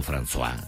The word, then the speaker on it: François: Ah, François, los perfumes François, que se escribe Francois. Y se dice François.